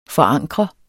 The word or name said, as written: forankre